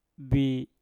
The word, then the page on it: bi: mpur